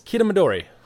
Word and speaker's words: Kitamidori: Yeah, so Belma is actually a daughter of Magnum and, I'm going to butcher this, Kitamidori. – Yes, you nailed it, actually.